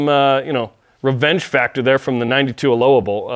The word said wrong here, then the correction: Aloha: Aloha Bowl, excuse me. I pronounce it like the city in Oregon.